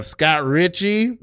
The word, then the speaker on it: Scott Richey: We have Scott Richey.